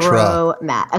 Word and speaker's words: Integromat: Is it inter or intra? Integromat. – Okay. Yeah, it's I-N-T-E-G-R-O-M-A-T.